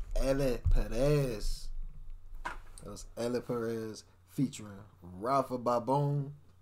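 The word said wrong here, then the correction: Pabón: Pabon and Xantos.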